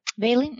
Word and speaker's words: valine: Now, what this means is that molecule that is breaking down the catecholamines is made up of, was it valine? Well, how do you say that? – Valine. Mm-hmm. – Valine.